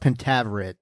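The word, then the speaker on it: Pentaverate: I think it's actually pronounced Pentaveret, is my understanding.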